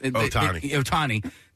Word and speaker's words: Ohtani: Like, how does somebody like Ohtani, I don't know if I said his name right. – Ohtani.